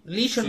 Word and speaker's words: LeSean: a ver si lo digo bien, LeSean, ¿no? LeSean, sí.